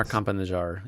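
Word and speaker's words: Campa-Najjar: The Democrat, Campo Najjar. If we got that pronunciation correct. – Amar Campanajar is running.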